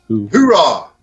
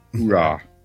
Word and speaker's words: Hooah: H-O-O-A-H. How you say it? – Hoorah! – Hoorah.